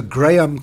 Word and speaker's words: Graeme: So Graham Codrington, look at the show notes for how to spell that, G-R-A-E-M-E, Codrington.